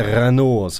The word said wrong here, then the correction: Renard: som han heter, tror jag uttalar rätt. Hervé Renard. Renard ska man nog säga.